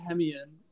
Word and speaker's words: Hemeon: Aaron Hemion, did I say it right? – That close enough. – Hemion, all right, you have to now say your last name for me and for our listeners. – Yes, it's Aaron Hemion.